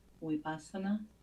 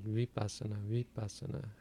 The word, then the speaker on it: vipassana: In Pali, are Vs pronounced as W, such as vipassana? Sometimes it sounds like you say vipassana. – It's about halfway between a V and a W, but in modern times it's more closer to a W.